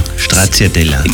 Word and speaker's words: Stracciatella: Noch so schön ausgesprochen. Stracciatella.